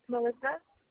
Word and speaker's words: Melissa: Oh, it's Melissa. Louise. – Okay, can I spell it for me, babe? – Melissa's M-E-L-I-S-S-A.